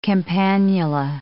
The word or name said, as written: campanula